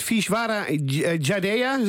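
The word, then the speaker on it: Vishwaraj Jadeja: De man heet Vishwara Jadeja. Zeg ik dat goed? – Ja, is goed hoor.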